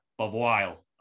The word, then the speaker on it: Bavoil: Today, I'm joined by the one and only Lya Bavoil.